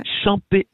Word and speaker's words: Champex: Quand ta fille est venue au Champex Estival, mm-hmm, Champex, le X ne se prononce pas. – C'est Champé Estival, mais le X ne se prononce pas.